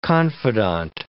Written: confidante

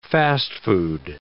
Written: fast-food

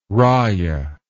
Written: rayah

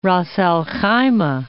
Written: Ras al-Khaimah

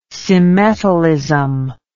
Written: symmetalism

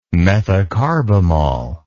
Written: methocarbamol

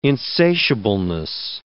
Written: insatiableness

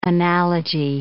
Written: analogy

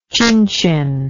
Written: chinchin

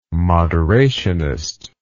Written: moderationist

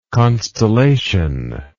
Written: constellatory

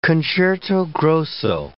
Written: concerto grosso

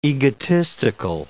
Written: egotistical

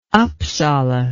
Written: upsala

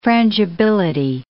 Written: frangibility

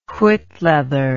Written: whitleather

